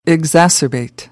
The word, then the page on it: exacerbate: American English; ɚ - ə